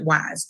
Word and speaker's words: Weis: Parents, since this is the parent support group, go to Weiss Market, W-E-I-S. People say Weiss. It doesn't matter. My mother pronounces it Wise.